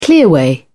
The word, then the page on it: clearway: British English